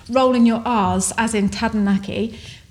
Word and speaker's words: Taranaki: Before we move on, let's just talk about the pronunciation of Taranaki, okay, because it is spelt Taranaki, and we, up until 4 months ago when we started doing YouTube, always called it Taranaki. Because that's what everyone else calls it. And then someone said, you know, we heard a Māori person pronounce it properly, and they said, oh, it's actually Taranaki, and it sounds so beautiful when it's pronounced the way it should be.